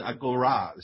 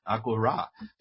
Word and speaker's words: agora: 后面这个叫 agora 啊，agora agora 这个词呢，这个词 agora 这个词呢啊，实际上发音呢应该是 agora 是这个意思。你看它的重音在后面 agora 这样的。